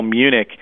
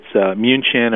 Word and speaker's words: München: You know, to most Westerners, in say, especially in the U.S., we call Munich Munich. To most people in Europe, you know, it's München or München. You know, it's sort of the German pronunciation or writing of that.